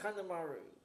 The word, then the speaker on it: Kanemaru: Kanemaru, I think, is his name. – Yeah, Yoshinobu Kanemaru. – Well, at least I pronounced his name correctly.